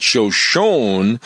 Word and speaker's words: Shoshone: And I've always equated that Shoshone is pronounced that way because of the E at the end of the sentence. Now, right. If I'm wrong, correct me on that. – That sounds right.